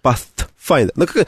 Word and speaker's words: Pathfinder: А Pathfinder? – Ну, потому что пишется через TH, поэтому это звук... – Это не Z, нет, это... – Да, это там потом глухой звук. – Pathfinder.